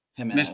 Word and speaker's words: Jimenez: This is in order: Mookie Betts, Jose Altuve, Marcus Semien, Luis Arias, Marte, Matt McClain, Gimmons. I'm sorry, I'm Jimenez. Mispronouncing these. Jimenez, Eduardo Yulian, Zach Yolov, and Nico Horner.